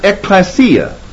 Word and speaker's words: ekklesia: The Greek word that is translated church is the word ekklesia, E-K-K-L, long E-S-I-A. Ekklesia.